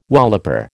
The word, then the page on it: walloper: American English